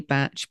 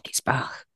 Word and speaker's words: Bach: I always say Bach, but some people say batch, but I think it's Bach.